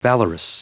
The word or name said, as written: valorous